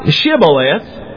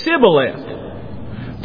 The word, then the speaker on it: Shibboleth: If he said, nay, then said they unto him, say now Shibboleth. And he said, Sibboleth. For he could not frame to pronounce it right.